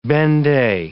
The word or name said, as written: benday